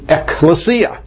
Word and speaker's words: ecclesia: But we would pronounce it ecclesia.